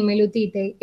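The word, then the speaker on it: Meilutytė: De Ruta, sí, se pronuncia así, Mailutite.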